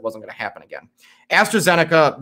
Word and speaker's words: AstraZeneca: But on top of that, and I'm going to AstraZeneca. Okay, that is how you pronounce it.